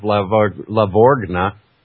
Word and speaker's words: Lavorgna: Yeah, good boy, they're going to buy $1 trillion of treasuries, said Joseph Lavorgna. It's kind of like bologna with an R thrown in it.